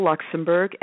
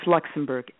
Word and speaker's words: Luxenburg: And it's Cheryl Luxenburg, and it is spelled S-H-E-R-Y-L. And my last name is Luxenburg, L-U-X-E-N-B-U-R-G.